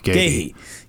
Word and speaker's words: Gueye: You know, getting how do you say that Chelsea player's name? Gueye? – How dare you? The disrespect for Mark Gahey. – Yes, Gahey and Anderson. – Gahey.